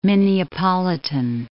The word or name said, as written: Minneapolitan